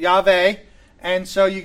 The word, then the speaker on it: Yahweh: It is a four letter word, yod, hey, vod, hey, and those four letters we think would most properly be pronounced Yahweh. Or Yahweh, right?